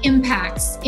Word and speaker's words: Impax: IMPAX? Is that how you guys pronounce it? – IMPAX, Impact Asset Management, which in the US, you know us as PAX World Funds.